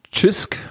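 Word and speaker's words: Cisk: Die haben ja ihr eigenes Bier, das heißt irgendwie Chisk, glaube ich, wird es ausgesprochen. Das ist ein C, C-I-S-K.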